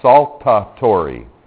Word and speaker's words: saltatory: And let me say this because the pronunciation, well, it's pronounced just as it's spelled, right? But it's kind of a funny spelling. It's called saltatory conduction. Okay, like table salt.